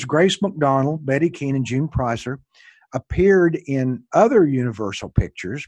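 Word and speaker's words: Preisser: Did you say her name Pricer, Michael? – June Pricer, yeah. – Pricer is how you say that name.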